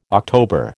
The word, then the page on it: October: American English